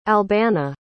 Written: albana

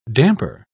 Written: damper

